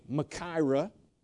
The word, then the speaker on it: machaira: M-A-C-H, A-I-R-A, Makaira, okay?